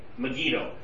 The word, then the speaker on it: Megiddo: Megiddo. I've got to pronounce it right. Megiddo.